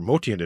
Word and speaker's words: Motian: It's most often pronounced Motian, and I always had done that until I watched quite an excellent 2020 documentary called, depending how you pronounce it, Motian in Motion or Motian in Motion. At the end of the documentary, his relatives say the correct pronunciation is Motian.